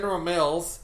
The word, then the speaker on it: General Mills: Meals? – I am pronouncing that weird. – Meals. – Anyway. General Mills.